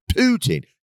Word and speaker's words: Putin: Putin.